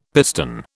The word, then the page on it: piston: British English